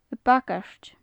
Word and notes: a bagairt: gaelic

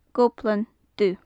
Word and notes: goblan-dubh: gaelic

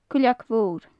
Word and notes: cuileag mhor: Gaelic

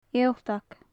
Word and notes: ialtag: gaelic

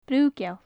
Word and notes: bru-geal: gaelic

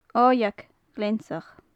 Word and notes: oigheag ghleansach: gaelic

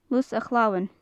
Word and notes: lus a chalmain: gaelic

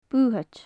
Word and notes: buthaid: gaelic

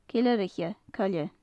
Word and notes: ceileiriche coille: gaelic

